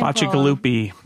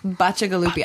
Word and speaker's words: Bacigalupi: Bacigalupi. – Bacigalupi.